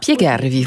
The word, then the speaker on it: Spiegarvi: En tout cas, cet air, c'est Vorrei Spiegarvi, si je le prononce bien. – Spiegarvi, vous expliquez.